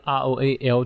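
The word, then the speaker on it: Roald: 然后，所以他的那个那个名字 R O A L D 的 D 是不发音的。然后他的名字念出来其实是 Roal。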